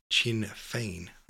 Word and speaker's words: Sinn Féin: It's pronounced like Chin Fein.